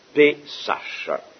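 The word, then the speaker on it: Pesach: Et le mot Pâque vient, vous le savez sans doute, du mot hébreu Pessach, qu'on peut écrire en prononciation française P et accent aigu S-A-C-H. Pessach.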